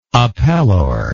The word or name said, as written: appellor